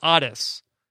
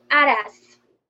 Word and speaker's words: Aras: Um, uh, Will Dennison wants to know uh, how do you pronounce Otis? Uh, do you pronounce it like Aras himself did in his confessional, or as Rob insists, it is Otis? – Uh, if I would say with a Brazilian um, way, Aras.